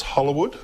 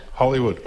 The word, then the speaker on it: Hollowood: Caitlin Hollowood, is it pronounced Hollowood? – Hollywood.